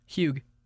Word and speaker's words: hygge: And the word is hygge.